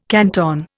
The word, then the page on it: canton: Indian